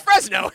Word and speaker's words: Frezno: Not California, but it is spelled F-R-E-Z-N-O. Yeah, it's Fresno. That's literally what it's called.